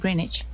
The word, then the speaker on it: Greenwich: El meridiano de Greenwich, ¿cómo se dice? – Greenwich. Yo le digo Greenwich. – Greenwich. Greenwich. Yo le digo Greenwich. Pero... Sí, claro, es Greenwich. sí.